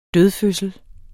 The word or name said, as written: dødfødsel